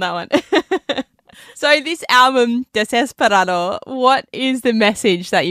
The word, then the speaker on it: desesperado: Yeah, in Spanish it would be Desesperado.